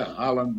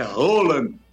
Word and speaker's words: Haaland: Aliás, a palavra, o nome não é Haaland, não. É Roland, viu, Tombaz? A pronúncia é Roland.